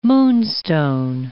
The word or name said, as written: moonstone